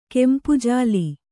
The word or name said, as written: ಕೆಂಪುಜಾಲಿ